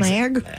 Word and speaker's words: Smaug: The name of the dragon in The Hobbit, is it's Smaug, S-M-A-U-G? Smaug? – I mean, Smag? I would have gotten it right, so I'll say yes, you're right.